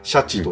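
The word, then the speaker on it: 鯱: うん、で、しかも、えー、魚の虎とかっていう、そのまま書いて、シャチと読むようなやつがいたと。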